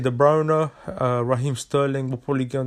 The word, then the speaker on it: De Bruyne: With guys like De Bruyne, which, yes, that's how you say his name, is not De Bruyne, because that's how he said it himself in an interview.